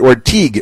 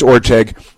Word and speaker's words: Orteig: So that's and again, they mispronounced it Orteig. It's Orteig, but that's okay.